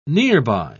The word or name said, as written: nearby